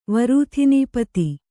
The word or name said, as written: ವರೂಥಿನೀಪತಿ